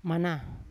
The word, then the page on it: mana: Yawa